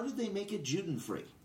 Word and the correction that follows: Judenfrei: Juden-free, it's actually pronounced Judenfrei, which means free of Jews.